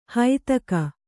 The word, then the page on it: ಹೈತಕ: haitaka